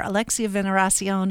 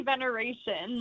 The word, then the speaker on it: Veneracion: And we have on the phone, in addition to Aaron Winter, who we've been talking to throughout this hour, Alexia Veneracion, who's the communications manager for Visit Colorado Springs. Did I say your name right, Alexia? – It's actually veneration. Like celebration.